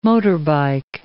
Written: motorbike

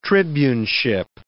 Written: tribuneship

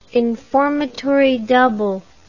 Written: informatory double

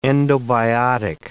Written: endobiotic

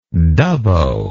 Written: dubbo

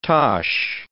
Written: tosh

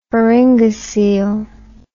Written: pharyngocele

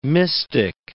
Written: mystic

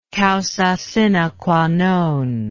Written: causa sine qua non

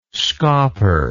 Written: scauper

